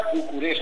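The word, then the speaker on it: București: Así que la Gasca Bucaresti. – Efectivamente. Gasca Bucuresti. Bucuresti. Bucuresti, efectivamente. Gasca, que es peña en rumano, y Bucuresti, que es como se pronuncia Bucarest en rumano.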